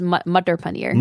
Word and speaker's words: matar paneer: It's pronounced mutter paneer.